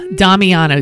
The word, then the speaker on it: Damiano: Damiano? – Yeah, Damiano. D-A-M-I-A-N-O.